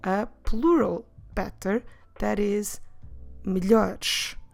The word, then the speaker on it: melhores: Now that you know how do we write them down, notice that we're dropping, when we're saying on the plural form melhores, we're dropping both es.